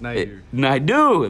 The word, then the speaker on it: Naidoo: Almost, Naidoo.